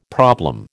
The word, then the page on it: problem: American English